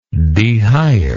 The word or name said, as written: dehire